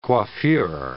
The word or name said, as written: coiffure